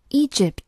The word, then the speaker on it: Egypt: 继续往下看，Egypt 名词表示埃及，注意它的发音，重音在首字母前面，读作 Egypt。